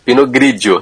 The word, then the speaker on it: Pinot Grigio: É Pinot Grigio, é isso? – Pinot Grigio. – Grigio, Grigio. – É Grigio. Ou em francês, Pinot Gris.